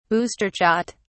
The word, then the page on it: booster shot: British English